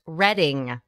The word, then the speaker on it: Reading: is pronounced Reading, Pennsylvania. – Oh, yes. Yes. I'm sorry. I've been mispronouncing that.